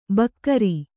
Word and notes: ಭಕಾರ: Kannada

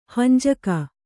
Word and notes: ಹಂಜಕ: kannada

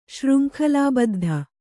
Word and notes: ಶೃಂಖಲಾಬದ್ಧ: śřnkhalābaddha

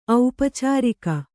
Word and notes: ಔಪಚಾರಿಕ: aupacārika

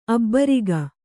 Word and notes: ಅಬ್ಬರಿಗ: abbariga

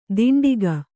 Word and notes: ದಿಂಡಿಗ: diṇḍiga

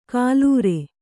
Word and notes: ಕಾಲೂರೆ: kālūre

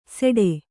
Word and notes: ಸೆಡೆ: kannada

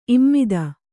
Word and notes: ಇಮ್ಮತ್ತಿ: Kannada

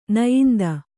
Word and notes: ನಾಯಿಂದ: nāyinda

